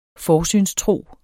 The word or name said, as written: forsynstro